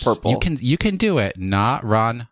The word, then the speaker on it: Naranja: It is here where players will either find the Naranja Academy, which I think is Spanish for Naranja. Yes.